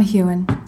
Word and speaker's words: Heughan: It's Sam Heughan.